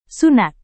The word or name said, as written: sunat